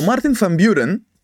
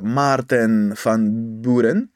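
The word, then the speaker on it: Maarten van Buren: Λοιπόν, ο Μάρτιν Φαμπιούρεν, ο οποιος ναι. στα Ολλανδικά ε, προφέρεται πιο πολύ ως ε, Μάρτεν Φαμπιούρεν, γεννήθηκε το 1782 στο Old Kinderhook, το οποίο βρίσκεται ναι.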